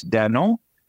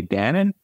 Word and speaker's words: Danone: In fact, one of the biggest dairy companies in the world, they pronounce it in France, Danone. In the United States, we say Danone.